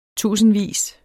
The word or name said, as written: tusindvis